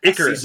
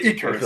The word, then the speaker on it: Icarus: That I mean, it was like Icarus. Is that the pronunciation? He went so high, and then he got burned by the sun so quickly because he was having Icarus. Season. – Yeah, Icarus.